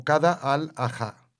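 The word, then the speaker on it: Qada al-Haja: En esta regla es conocida como Qada al-Ajá. – Uh-huh. – Lo he pronunciado bien, creo. – Sí.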